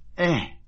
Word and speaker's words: Eh: His name is Stanley Eh.